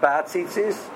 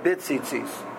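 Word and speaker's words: Bit Tzitzis (בציצית): He writes that it should be written, pronounced with a Shvo under the bays, not Bat Tzitzis, but Bit Tzitzis.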